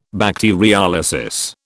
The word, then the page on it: bacteriolysis: British English